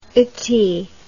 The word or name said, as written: ity